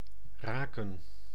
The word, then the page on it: meet: /miːt/